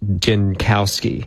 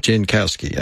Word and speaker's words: Jankowski: I think his last name is pronounced Jankowski. – Jankowski, okay. Yeah. So, but I think it's a J.